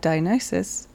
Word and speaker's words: Dionysus: In more recent Western history, the Greek god Dionysus...